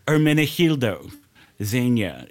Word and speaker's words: Ermenegildo Zegna: Ermenegildo Zegna.